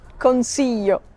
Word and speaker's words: Consiglio: Oh, how do you say your last name, please, Michela? Consiglio? Consiglio. Consiglio. – Consiglio. – Yes.